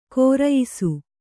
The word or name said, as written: ಘೇರಾಯಿಸು